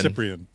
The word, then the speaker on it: Cyprian: Okay, so how did you say it? – Cyprian. – Cyprian. – Cyprian. Yeah. Okay.